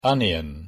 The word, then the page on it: onion: English